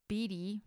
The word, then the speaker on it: Bede: The venerable Bede, I don't know how to pronounce his name. It's B-E-D-E, Bede, Bede. I'm going to call him Bede.